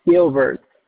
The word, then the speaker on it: Gilbert: My name is Gilbert. – All right. You said Wilbert? – Gilbert. – Can you spell that for me? – G-I-L-B-E-R-T.